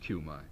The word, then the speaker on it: Cumi: Would that I had known Aunt Cumai Woody, C-U-M-I, pronounced Cumai.